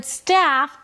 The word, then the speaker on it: staff: So, what I think you should do is focus on pronouncing the word staff, focusing on the a as in cat.